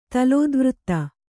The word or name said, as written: ತಲೋದ್ವøತ್ತ